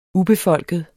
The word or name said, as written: ubefolket